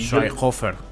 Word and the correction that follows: Schweighofer: ¿Cómo, cómo? – Tío, te salió. Schweighofer Schweighofer.